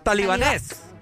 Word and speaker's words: talibanes: talibanes. talibanes, creo. – Talibanes. ah, me, perdón. qué indio me vivo. talibanes, perdón. Ah. es que fíjate que acá tienen, tienen el, acento en la E.